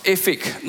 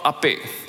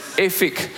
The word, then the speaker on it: Aphek: Now, it's pronounced Aphek, not Ape. Some of you Hokkien dialect, you know, Ape is a name for older men. It's Aphek.